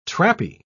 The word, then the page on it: trappy: British English